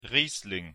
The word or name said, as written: Riesling